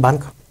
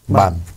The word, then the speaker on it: Bahn: Όχι bank. – Bahn.